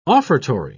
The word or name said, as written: offertory